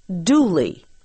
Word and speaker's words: Dula: An example is the story of a young man whose last name was spelled D-U-L-A, but pronounced Dooley.